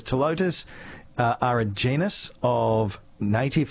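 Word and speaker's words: Ptilotus: A couple of text messages about, and I'm going to pronounce this telotus, even though it starts with a P, because I don't know, okay? – Yes.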